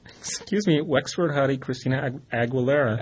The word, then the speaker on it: Aguilera: Aguilera.